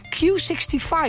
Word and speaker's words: Q65: Het is een nummer van wat we gaan draaien van Q65.